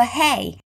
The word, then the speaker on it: hay: Hit the hay. Hey here is pronounced the same way as our greeting, our between hello and hi, hey, but it's spelled with an A and it means fennel, like the thing you feed horses.